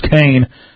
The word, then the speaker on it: Khan: And Kane was seized, kidnapped, spelled Khan, pronounced Kane.